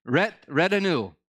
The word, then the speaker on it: retinue: If you don't learn anything, you're going to learn this retinue. That's how you pronounce it. Maybe. But it's retinue, and it means a group of advisors, assistants, or others accompanying a important person.